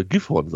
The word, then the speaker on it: Gifhorn: Dürfte dann sogar Gifhorn sein, mit Betonung auf der ersten Silbe. – Oh, Entschuldigung, Gifhorn, ja.